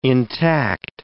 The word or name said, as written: intact